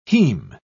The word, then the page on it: heme: British English